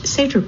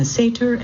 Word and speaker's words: Seter: Still, experiencing a Sætre as a visitor requires the generosity of a guide and a pair of sturdy, waterproof boots. Luckily, I had both. Sætre is spelled S-E-T-E-R.